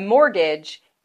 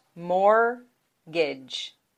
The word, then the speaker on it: mortgage: A mortgage is the loan that you get for a home. It is pronounced mortgage. Mortgage.